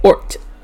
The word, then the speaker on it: ort: Okay, this one is ort. Yes, ort. O-R-T. It's just pronounced ort. I checked.